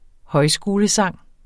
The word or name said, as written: højskolesang